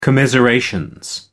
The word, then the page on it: commiseration: American English